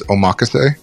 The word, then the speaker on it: omakase: I'd say the correct way of saying it, I've heard more online from actual Japanese people, is omakase. – Omakase. – Omakase. I would say Americans say omakase. It almost sounds like a Spanish word more than a Japanese word when they say it.